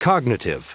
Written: cognitive

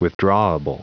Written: withdrawable

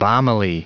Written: balmily

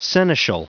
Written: seneschal

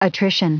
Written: attrition

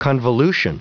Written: convolution

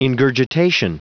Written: ingurgitation

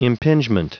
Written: impingement

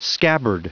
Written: scabbard

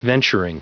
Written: venturing

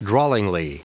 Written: drawlingly

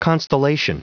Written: constellation

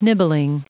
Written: nibbling

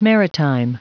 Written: maritime

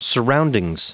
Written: surroundings

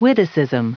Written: witticism

